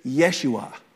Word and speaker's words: Yeshua: That's the English pronunciation of it. The actual pronunciation is Yeshua. It's the same Hebrew pronunciation that is the word Joshua in the Old Testament.